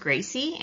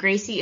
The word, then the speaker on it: Gracy: Yeah, it's Self-Care with Gracy, and Gracy is spelled Gracy.